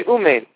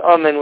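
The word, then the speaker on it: Amen (אָמֵן): Correct pronunciation is Amen with a Kamet Gadol.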